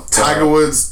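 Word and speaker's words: Taiga Woods: First song was, I think it's pronounced Tiger Woods, is that right? – I believe so. – Tiger Woods, T-A-I-G-A Woods.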